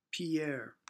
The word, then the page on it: Pierre: English